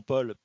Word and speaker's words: Paul: Alors, le prénom Paul, P-A-U-L, Bechet, B-E-C-H-E-T.